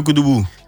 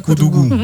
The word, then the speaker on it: Koudougou: Depuis Koudougou. – Depuis Koudougou. – Ah, ben c'est Koudougou. – Non, oui, elle a dit Koudougou. Elle a bien prononcé.